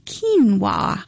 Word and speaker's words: quinoa: It is pronounced, it is spelled Q-U-I-N-O-A, so it doesn't necessarily sound like what quinoa would be pronounced like.